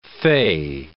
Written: fey

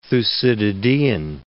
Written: Thucydidean

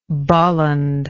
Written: bolland

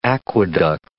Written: aqueduct

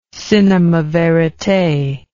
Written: cinema verite

